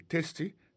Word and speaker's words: tasty: Tasty, T-A-S-T-Y. – T-Y, ça veut dire goût, goût dans bouche. T-A-S-T-Y, vous prononcez le testi, ça veut dire goût dans bouche.